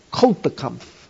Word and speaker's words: Kulturkampf: Kulturkampf, German word, cultures in conflict.